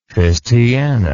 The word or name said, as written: fistiana